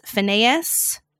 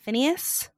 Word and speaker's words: Finneas: Phineas, F-I-N-N-E-A-S, Phineas. – Yeah, Phineas, I think. – Phineas.